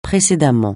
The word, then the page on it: précédemment: francais